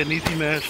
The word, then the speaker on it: Niecy Nash: I watched Drop the Mic, and I actually, Niecy Nash, am I saying it right? – Yeah, Niecy Nash and Cedric.